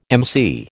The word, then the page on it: emcee: American English